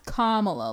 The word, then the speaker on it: Kamala: It's pronounced Kamala, like calm, stay calm.